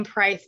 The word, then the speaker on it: Preis: My last name Price is spelled P-R-E-I-S.